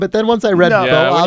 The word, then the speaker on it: Boops boops: Unfortunately, Boops Boops is actually pronounced Bo-ops. Bo okay. as in bovine, ops as in optical, hence cow eyes, Steve."